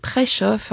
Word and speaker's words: Preszow: Nous avons commencé en musique aujourd'hui pour changer un peu avec Noé Preshow, P-R-E-S-Z-O-W, qui est prononcé Preshow, un bruxellois, auteur, compositeur, interprète et musicien, qui avait été nominé aux Victoires de la Musique en 2021 comme révélation masculine avec son premier album, À nous, et son deuxième album qui s'intitule Preshow, écrit P-R-E-C-H-O-F, je suppose, pour aider les gens à prononcer son nom. Alors aujourd'hui très heureuse de vous retrouver et j'ai le plaisir d'avoir comme invité nul autre que Céline de Roani.